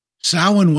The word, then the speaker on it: Samhain: But going from there into talking about Samhain, how did you pronounce that word? S-A-M-H-A-I-N. – I mispronounced it, but I just pronounced it phonetically. – Okay. Yeah. From the Celtic lands, Welsh and things of that nature, nothing is ever pronounced phonetically, I have found.